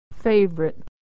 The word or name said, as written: favorite